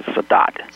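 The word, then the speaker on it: Sadot: I know my name's VICSADOT3 and you don't pronounce that T in the regular way, but to get people to get it right, I'm going to pronounce it Sadat. Even though it grates on my ears.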